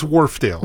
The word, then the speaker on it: Wharfedale: is it Wharfedale? How do you say it? – It's Wharfedale.